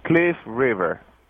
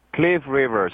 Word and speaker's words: Cliff River: My name is Cliff River. Cliff River, C-L-I-F-F, last name River, R-I-V-E-R.